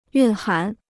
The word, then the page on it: 蕴涵: yùn hán